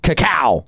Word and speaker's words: cacao: It's spelled C-A-C-A-O. Cacao. – Cacao. – You are right. It is cacao.